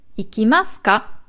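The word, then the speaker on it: 行きますか: Und dann ändert sich auch die Betonung. Ikimas. Ikimas ka. Da da da da. Da da da ist ikimas.Ikimasu. Ikimasu ka? Da geht's dann hoch.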